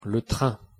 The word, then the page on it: le train: French